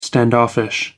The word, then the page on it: standoffish: American English